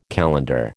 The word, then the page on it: calendar: American English